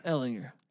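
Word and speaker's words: Ehlinger: Texas feels like was Sam Ellinger. Ellinger. What's the ruling on that? How do you say his last name? – Ellinger.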